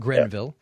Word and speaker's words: Grenville: Lee Montgomery plays a Phil Greenville or Grenville. I don't know how you pronounce it. – Grenville. – Yeah. Kind of like Grendel.